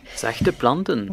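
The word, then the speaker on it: Zachte planten: Je ne sais toujours pas si je le dis bien. – Zachteplanten. – Voilà.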